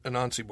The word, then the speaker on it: Anansi: Weirdly, that's the book I have is the leather-bound edition of American Gods and Anansi Boys.